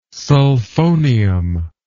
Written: sulfonium

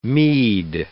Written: Mede

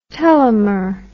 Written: telomer

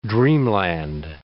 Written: dreamland

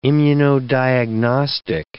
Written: immunodiagnostic

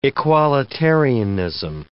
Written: equalitarianism

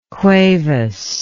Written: cuevas

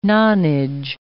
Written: nonage

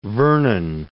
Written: Vernon

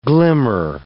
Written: glimmer

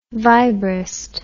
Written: vibrist

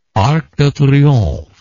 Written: Arc de Triomphe

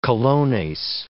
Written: colones

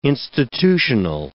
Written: institutional

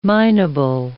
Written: mineable

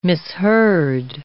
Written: misheard

